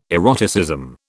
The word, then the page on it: eroticism: British English